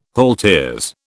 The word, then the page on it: halteres: British English